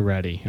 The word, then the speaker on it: RHETI: was the the RHETI. Is that how you say it? – The RHETI.